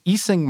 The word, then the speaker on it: Ising: There's actually a kind of classic ideal model called the Ising model. Not unlike Clique, it has another pronunciation, which is the Ising model.